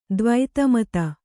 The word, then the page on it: ದ್ವೈತಮತ: dvaita mata